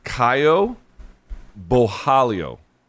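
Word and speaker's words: Caio Borralho: My understanding is it's Caio Bojalio. – Bojalio. – So two R's in the middle would be an H, Bojalio.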